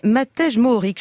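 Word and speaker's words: Matej Mohorič: Un Slovène qui n'est pas en jaune, qui ne s'appelle pas Pogacar, mais Matej Mohoric, je ne sais pas si je le prononce bien d'ailleurs.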